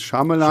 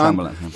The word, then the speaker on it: Shyamalan: Shyamalan, Schamelan, ihr dürft ihn aussprechen, wie ihr wollt. Ich glaube, die richtige Aussprache ist Schamelan. – Schamelan, ja.